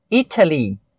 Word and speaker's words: Italy: Uma pronúncia mais britânica, ou seja, falado por quem vem da Inglaterra ou de países da Grã-Bretanha, seria Italy, exatamente com o som de T, que para a gente fica normal, entre aspas.